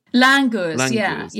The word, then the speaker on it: Langurs: But you spent some time with a scientist and with some, is it Langurs? Is that how you pronounce it? – Langurs, yeah.